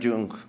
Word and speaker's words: Jung: Yo creo que sí está A ver, ¿esto cómo lo, cómo lo pronunciabas tú, el Jung? Aquí, el niño Carl. John. Carl Jung. Carl Jung.